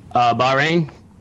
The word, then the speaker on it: Bahrain: Let's talk about Bahrain. I believe that's how you pronounce it. That was one of your first deployments out, right? – Bahrain? – Yes. – Yes, sir.